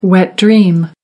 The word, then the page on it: wet dream: American English